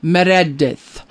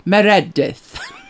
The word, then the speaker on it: Meredith: And I said, you know, Meredith. And he said, Meredith. I'm Welsh. Meredith.